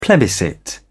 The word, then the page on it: plebiscite: British English